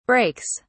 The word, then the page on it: brakes: British English